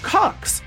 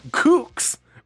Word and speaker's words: Qux: It's called, now it's written Q-U-X, but it's pronounced Cux. And in fact, now that you said that, Swin, I realize the other option is pronouncing it Kooks, which might also be appropriate, but it's Cux.